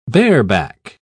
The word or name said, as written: bareback